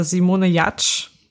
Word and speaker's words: Simone Giertz: Okay, meine Person der Woche ist äh, Simone Jatsch. Sie wird zwar so ausgesprochen, aber sie wird anders geschrieben. Sie wird Simone Giertz geschrieben.